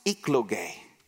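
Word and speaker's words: ekloge (ἐκλογή): That word chosen in Greek is pronounced ekloge, which we get our English word election.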